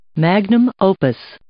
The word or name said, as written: magnum opus